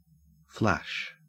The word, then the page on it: flash: Australian English